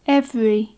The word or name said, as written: every